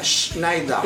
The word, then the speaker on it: Schneider: La E y la R cuando van juntas al final, como en el apellido este loco, no se pronuncia. – No, mo. – Entonces el apellido sería Schneider.